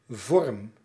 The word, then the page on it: vorm: /vɔrm/